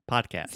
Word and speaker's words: podcast: The first this is a hard D pod. Also the first vowel sound is an open ah, just like prato chat podcast. It's not podcast, but podcast.